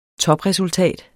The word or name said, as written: TOPRESULTAT